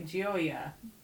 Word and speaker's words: Gioia: Gioia.